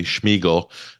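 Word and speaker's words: Shmyhal: And Denise Schmiegel, no, I said that correctly, Schmiegel,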